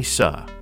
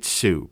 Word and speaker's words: Suh: The correct pronunciation is Suh. I grew up Sue, and I blame that on my parents. You know, it's more of an Americanized version of the last name.